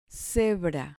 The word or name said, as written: cebra